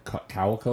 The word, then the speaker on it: Cuoco: Kaylee Cuoco. I don't even know how to say her last name. Cuoco or whatever.